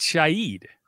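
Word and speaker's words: Shaheed: Yes, I think it's Rashid Shahid. – Really? – Like the H is silent.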